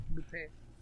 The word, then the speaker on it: Lupin: Lupin? L-U-P-I-N or what? – Yeah, yeah. Lupin, but in French it's Lupin.